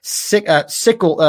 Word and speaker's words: Scytl: which I believe is pronounced Sickle. I thought I had it correctly. SCYTL. It's a very interesting name.